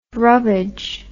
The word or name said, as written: rubbage